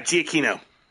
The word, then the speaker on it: Giacchino: Yeah, Giacchino.